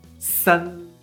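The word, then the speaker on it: san (三): El número 3 en chino es S-A-N, en primer tono. Se pronuncia san, san, san. ¿Notas? Esa S de Sevilla.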